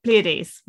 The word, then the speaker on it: Pleiades: I could pronounce this earlier. What's wrong with me now? Pleiades. There we go.